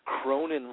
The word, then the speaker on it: Cronin: What does Cronin rhyme with? – Conan. – Cronin rhymes with Conan.